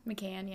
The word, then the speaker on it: McCann: I think I'm saying that right. Madeline McCann. – McCann, yeah.